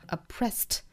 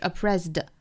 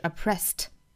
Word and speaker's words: oppressed: 那它加上了这个 ed 呢，变成过去分词形式哈，我们把它读成这个 oppressed，它的尾音呢应该是一个 t 的清辅音，不要把它读成了 d，不是 oppressed 而是 oppressed oppressed by somebody else。